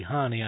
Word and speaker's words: Hahne: It's spelled H-A-H-N-E, so I'm going to say Hahn. Could be Hani, I suppose.